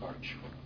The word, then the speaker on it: Bartsch: David Bartsch. B-A-R-T-S-C-H.